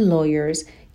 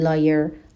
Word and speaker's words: lawyer: یعنی نسبت به همتایان خودشون احتمالش بیشتر هستش که to become engineers and lawyers که مهندس و وکیل بشن. به تلفظ کلمه lawyer هم دقت کنین، نگین liar. liar یعنی دروغگو، lawyer یعنی وکیل.